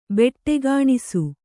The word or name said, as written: ಬೆಟ್ಟೆಗಾಣಿಸು